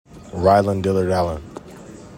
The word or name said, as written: RYLON DILLARD-ALLEN